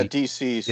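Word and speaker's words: DCeased: Is it deceased or DC? – Yeah, deceased. Yeah.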